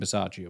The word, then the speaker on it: Bisaccia: Well, we hired him, and you pronounce his name Bisaccia, which I guess it's always one of those things where once you find out, it's like, I should have known that.